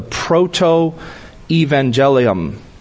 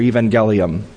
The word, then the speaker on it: proto-evangelium: Now, Bible teachers will often call Genesis 3:15 the proto-evangelium, or evangelium. You'll hear people pronounce it either way. Proto, P-R-O-T-O, evangelium, E-V-A-N-G-E-L-I-U-M, E-V-A-N-G-E-L-I-U-M.